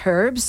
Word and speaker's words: Herbs: Going back into the stories, our next track is by The Herbs. So we're getting, yes, into Kiwi pronunciation. It's Herbs. And getting into Tama London, who actually passed away not long ago.